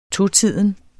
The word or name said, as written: totiden